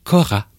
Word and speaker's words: kora: Vous disiez qu'il faut prononcer Kora. – K-O-R-A.